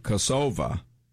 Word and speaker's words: Kosovo: Now, one of the things that Blarina did while she was living in her native country, Kosovo, isn't that the way you pronounce it? – Yes, it is.